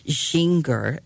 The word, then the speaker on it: Gingher: And this one is by Marianne Ginger, I think you say her name, G-I-N-G-H-E-R. She was born in Guam in the Mariana Islands and grew up in Greensboro, North Carolina.